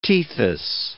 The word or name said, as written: Tethys